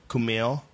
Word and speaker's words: Kumail: Kumail.